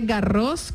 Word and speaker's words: Garros: Ahí va la primera casperita, se dice, bueno, se dice Garros con S al final porque es nombre ¿sí? propio. Sí, en francés la S no se pronuncia, pero esta sí.